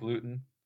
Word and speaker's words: Luton: uh, but I'm glad you brought this guy in, Jake Luton. Luton. Is it Luton or Luton? Luton we know. Luton. Luton. Yeah. It would make sense to rhyme with gluten.